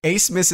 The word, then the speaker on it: Abmas: Listen, Acebus is the last name of the nation's... – Midcourt Max. – That's the last name of the nation's leading scorer, whom I was unfamiliar with. I mean, I was familiar with him, but I was not familiar with the pronunciation of his last name until... – Yeah. – I think it was Jay Billis who brought it to my attention that you don't pronounce the B there.